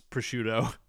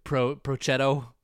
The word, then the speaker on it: prosciutto: Steve struggles to pronounce prosciutto. Prochetto.